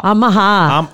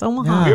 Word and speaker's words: Omaha: It was like, like the emphasis was on the wrong syllable or something. And I kept hearing it on one of the national. I'm like, what is wrong with this? It's Omaha.